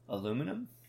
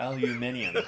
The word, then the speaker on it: aluminium: I was like, so, aluminum or aluminium? – Aluminium. – It's like aluminium. Like, where does that extra... vowel come in there and you added letters to that.